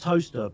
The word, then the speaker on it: Towcester: It's actually pronounced toaster, but that sounds weird.